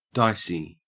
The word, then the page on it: dicey: Australian English